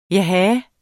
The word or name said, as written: jaha